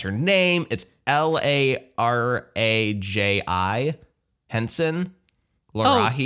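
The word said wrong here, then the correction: Taraji: Oh, I think that's, I think it's a T. – Oh, it is. It is a T. It is a T. Tarahi Henson.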